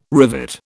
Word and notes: rivet: British English

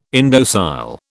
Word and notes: indocile: British English

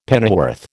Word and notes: pennyworth: American English

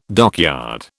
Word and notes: dockyard: British English

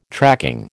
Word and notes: tracking: American English

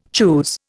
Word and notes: choose: Indian

